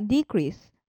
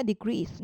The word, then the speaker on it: decrease: 例えば、減少という名詞でしたら decrease なんですけれども、減るという動詞の場合は decrease になるですとか、輸送という名詞でしたら transport と、アクセントが前に行きますけれども、輸送するという動詞になると transport になって後ろに移動するんですね。